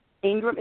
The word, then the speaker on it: Ingram: Ingram is I-N-R-A-N. Excuse me, I-N-G-R-A-M, Ingram.